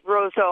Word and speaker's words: Roseau: Am I pronouncing that name correctly? – Yes, Roseau.